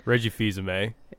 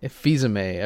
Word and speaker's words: Reggie Fils-Aimé: Reggie Field. I never know how to say his name. It's like Reggie Fils-Aimé. – Fils-Aimé. Okay.